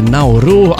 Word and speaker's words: Nauru: Nauru, pronunțat în mod obișnuit Nauru, a devenit numele oficial al insulei, deoarece numele său indigen nu putea fi pronunțat corect de limbile străine.